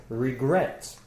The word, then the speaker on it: regret: Regret. – Exactly. Regret has two syllables. Regret. The stress is on gret, the last one.